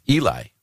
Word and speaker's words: Eli: E-L-I, which we would all, Americans would say, that sounds like Eli, right? – Mm-hmm. – And their birth announcement said his name is Eli, which rhymes with jelly.